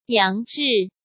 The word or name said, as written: Yang Zhi